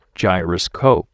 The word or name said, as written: gyroscope